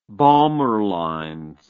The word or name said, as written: balmer lines